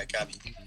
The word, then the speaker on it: Gabi: So, your name is pronounced Gabi, correct? – Yeah, Gabi.